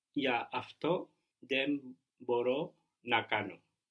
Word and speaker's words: μπορώ: What was I can or I'm able to, was a bit of an odd verb in that it carried the accent on the ending. Do you remember how that was? I can? – Μπορώ.